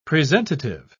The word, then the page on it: presentative: British English